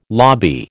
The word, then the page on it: lobby: American English